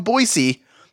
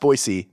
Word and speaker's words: Boise: Cheers from Boise. Carly, P.S. It's pronounced Boise, not Boise.